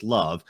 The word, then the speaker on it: LOVB: How did League One Volleyball, or the acronym LOVB, which is pronounced LOVB, how did LOVB start? – LOVB started really between friends.